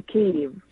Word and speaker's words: Kyiv: We say Kyiv, because you have to put it like K-Y-I-V, because it's Ukrainian spelling of the name of Kyiv. And K-I-E-V right. – is Russian Soviet name of pronouncing it.